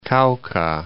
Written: Cauca

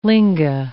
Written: linga

